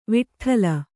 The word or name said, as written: ವಿಟ್ಠಲ